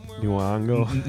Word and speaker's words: Nyong'o: I'm sorry in advance because I feel like I'm gonna butcher her last name, but Lupita Nyong'o. – Nyong'o.